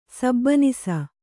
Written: ಸಬನೀಸು

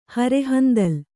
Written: ಹರೆಹಂದಲ್

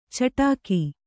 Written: ಚಿಟಿಕೆ

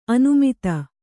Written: ಅನಾಮತ್ತು

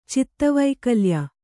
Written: ಚಿತ್ತವೈಕಲ್ಯ

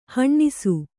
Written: ಹಣ್ಣಿಸು

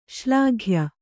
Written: ಶ್ಲಾಘ್ಯ

